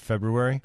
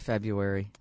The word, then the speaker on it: February: February? – I say February. – I do, too. It's hard to drop that extra R in there.